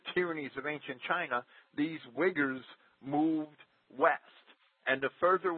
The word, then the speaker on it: Uyghurs: And there's a people called, I like to call them the Uyghurs, right? And okay. – that's abusing the pronunciation of the word. – Sure. – It's Uyghurs or something similar to that.